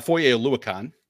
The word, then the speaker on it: Foye Oluokun: It's Fozé, how do you say his name? Fozé Foye Oluokun.